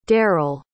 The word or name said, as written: derell